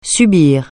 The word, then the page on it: subir: francais